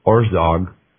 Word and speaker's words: Orszag: And now here's how you spell it. It's O-R-S-Z-A-G. So it's O-R-S-Z-A-G. I'm pronouncing it Orszag.